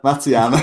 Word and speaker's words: Matsuyama: But just if there, just I don't want to miss any in case there is any, and then I have Hideki Matsuyama. I can never say his name. – I think you got it, Matsuyama.